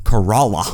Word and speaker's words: Corolla: We're in a town called Corolla. – Corolla. – Yeah, which is spelled like Toyota Corolla.